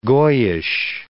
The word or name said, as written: goyish